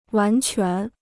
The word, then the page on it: 完全: wán quán